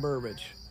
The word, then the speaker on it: Burbage: It's F-R-E-E-Q Comics. – Comics, okay. Yeah. – And on Facebook, it's Carolyn Burbage. – Carolyn Burbage. How do you spell your last name? – It's B-U-R-B-A-G-E.